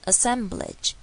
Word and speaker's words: assemblage: 好，water 也是，如果是美式发音会变成 water water，这边没有，就是 water water glittering assemblage assemblage。注意最后面这个 b l a g e 的地方是 edge，端母音 i 的声音 e e e blage，不是 blage 哦。